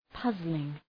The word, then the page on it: puzzling: ‘pʌzlıŋ